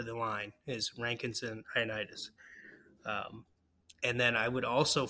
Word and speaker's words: Ioannidis: I may butcher the name, but Ioannidis, is that? – Yeah. Yep. That's right. Okay. Nailed it.